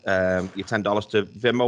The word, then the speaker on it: Venmo: Venmo.